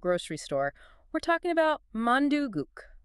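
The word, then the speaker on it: mandugook (만둣국): The pronunciation is pretty straightforward. It's mandugook.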